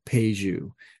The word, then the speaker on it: Pageau: His name is Jonathan Pajew. So Jonathan, his last name is spelled P-A-G-E-A-U, Pajew.